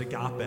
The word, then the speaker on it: agape: He talked about this word in the Greek language that is pronounced agape, and we call it love.